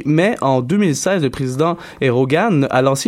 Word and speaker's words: Erdogan: Erdogan, pardon, toujours aussi difficile de le prononcer. – Erdogan.